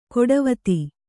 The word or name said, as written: ಗಾಡಿವತಿ